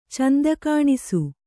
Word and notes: ಚೆಂದಕಾಣಿಸು: cenda kāṇisu

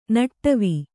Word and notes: ನಡುವು: kannada

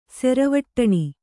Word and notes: ಸೆರವಟ್ಟಣಿ: Kannada

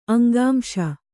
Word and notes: ಅಂಗಾಂಶ: Kannada; aŋgāmśa

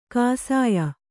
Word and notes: ಕಾಸಾಯ: kāsāya

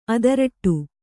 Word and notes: ಅದರಟ್ಟು: Kannada

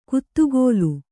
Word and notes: ಕುತ್ತುಗೋಲು: kuttugōlu

